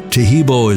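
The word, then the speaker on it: Taheebo: Tahibo is spelled T-A-H-E-E-B, like boy, O, then the word tea, and then the word club.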